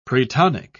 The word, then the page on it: pretonic: British English